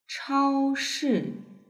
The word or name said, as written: chaoshi